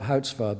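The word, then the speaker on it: Goudzwaard: One of the Dutch economists, his name is Houtvard, Bob Houtvard, but it's spelt with a G, but that's how the Dutch go.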